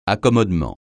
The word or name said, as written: accommodement